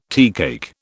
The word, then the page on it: teacake: British English